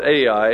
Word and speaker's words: Ai: In Joshua chapter 9, Israel's successes against Jericho and Ai. Urshel Shanks pronounces this I, but we're going to call it Ai.